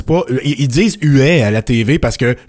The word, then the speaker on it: Huet: Mais le goaler lui-même, le gardien, le cerbère, lui-même, il dit que son nom, ça se prononce Uet.